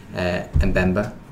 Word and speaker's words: Mbemba: Elsewhere, one defender I do want to mention, Chris, is Mbemba. Pronounced it right this time.